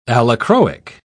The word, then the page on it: allochroic: British English